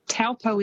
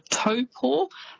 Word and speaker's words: Taupo: but famous spots including Taupo. So quite often you'll hear that said as Taupo. – Yes. – It's almost how it's spelt, but that Taupo is actually pronounced Taupo.